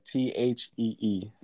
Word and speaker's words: Thee: Is it the? – Yeah, like THEE, The Sacred Souls.